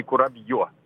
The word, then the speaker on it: курабье: А у меня самое любимое вот печенье, кстати, сейчас поправите меня, все хочу узнать, как оно правильно говорится, курабье или курабье? – Курабье, по-моему, я понятия не имею. Ну, то есть я... Ну, давайте посмотрим, вы пока говорите. Курабье все-таки, да. И ударение на последний слог, да.